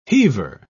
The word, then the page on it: heaver: British English